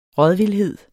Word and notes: rådvildhed: ˈʁʌðvilˌheðˀ; ˈʁɔð-

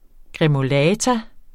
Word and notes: gremolata: gʁεmoˈlæːta; gʁεmoˈlɑːta

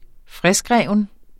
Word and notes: friskrevet: -ˌʁεˀvəð

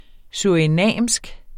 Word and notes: surinamsk: ˈsuɐ̯ˀiˌnɑmˀsg; suɐ̯iˈnæmˀsg

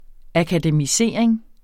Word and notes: akademisering: akadəmiˈseˀɐ̯eŋ; ɑka-

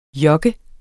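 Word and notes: jogge: Danish

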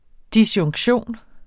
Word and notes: disjunktion: [ disjɔŋgˈɕoˀn ]

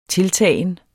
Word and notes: tiltagen: ˈtelˌtæˀjən

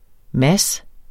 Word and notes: Mads: ˈmas

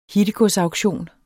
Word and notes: hittegodsauktion: dansk

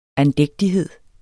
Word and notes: andægtighed: anˈdεgdiˌheðˀ

